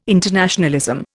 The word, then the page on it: internationalism: Indian